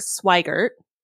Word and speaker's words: Swigert: So there was Jim Lovell, who was played by Tom Hanks in the movie, Fred Hayes, and then Jack Swigert. I got a thumbs up for pronunciation.